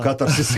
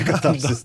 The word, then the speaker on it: катарсис: Ну, кстати говоря, сразу скажу, что Тимофей, ни я не ошибаемся, это слово имеет два ударения. – Да. – И катарсис, и катарсис. Да.